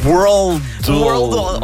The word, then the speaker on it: Worldle: Worldle, comme le world, comme le monde.